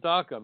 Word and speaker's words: DACA: Everyone knows what DACA means, maybe because it rhymes with caca.